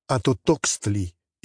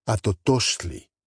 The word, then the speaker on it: Atotoxtli: It has the warmth of the language of the ancient Mexicans if you stress the right syllable and pronounce the X as SH, sh. Same with the other names. Atotoxtli is hideous if you pronounce it with a hard X. Atotoxtli, on the other hand, is easy to say and has a beautiful sound.